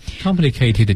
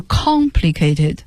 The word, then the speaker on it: complicated: complicated 就是很复杂的哈，就注意大家这个单词的读音，它的重读音节在第一个音节上，是 complicated。因为我教学生的过程中老听见有同学把它发音成 complicated，不对，重音不在 K 上面，是在 con 上面，复杂的形容词。